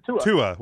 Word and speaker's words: Tua: Just stop after the first syllable and you're good. Tua.